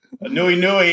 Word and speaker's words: Anuenue: Nui Nui is Hawaiian for rainbow. – So yes, look at this. – Some homework. – I'm going to tell you something. Your pronunciation was spot on. That was awesome. You know, I'm like a quarter Hawaiian, and I stumble on Hawaiian words, but that was actually really well.